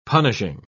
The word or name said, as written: punishing